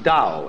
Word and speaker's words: Tao: That's pronounced Tao. If it had an apostrophe after it, it would be pronounced Tao. But Tao is more or less the right pronunciation for that word.